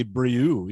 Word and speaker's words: Brioux: I'm stuck with it, Howard, but it's actually Brioux. You know, I mispronounced my own name, but it's been anglicized.